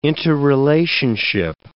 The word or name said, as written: interrelationship